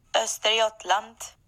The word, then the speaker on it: Östergötland: Östergötland.